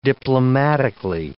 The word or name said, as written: diplomatically